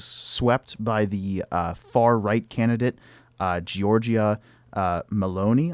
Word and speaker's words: Giorgia Meloni: So, yes, Giorgia Meloni, as they say.